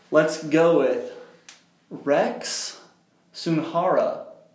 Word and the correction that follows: Sunahara: I think that's how you pronounce Sunhara? – It. – Sunhara? – Rex Sunahara. – Sunahara.